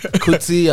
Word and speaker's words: Kuti: T-I-T-I. Woman's titties. Same pronunciation, so it's Titi. Wow. Kuti as in Fola Kuti, it's the same thing.